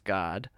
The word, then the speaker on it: GAAD: Another thing that I invented is a name for God, which is an acronym, and it's spelled G-A-A-D, but it's pronounced God, and it stands for gratitude, attention, acceptance, and discernment.